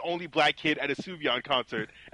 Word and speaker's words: Sufjan: It's written Sufjan, but it's pronounced Sufjan. Yeah, it's Sufjan Stevens.